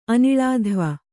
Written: ಅನಿಳಾಧ್ವ

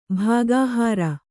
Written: ಭಾಗಹಾರ

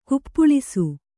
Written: ಕುಪ್ಪಳಿಸು

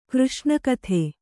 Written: ಕೃಷ್ಣಕಥೆ

